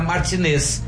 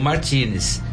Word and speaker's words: Martínez: Quando você termina um nome com S, é Martinez. Como o nome do cara é Martinez com Z, você tem que pronunciar Martinez. mas os caras pronunciam Martínez.